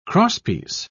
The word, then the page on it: crosspiece: British English